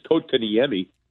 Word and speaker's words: Kotkaniemi: It is Kotkaniemi is how you say that one, but so yeah, wasn't even close.